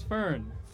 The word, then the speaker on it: Fern: It's a one-syllable name. It's Fern.